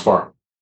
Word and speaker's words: FIR: FIR, but it's pronounced far.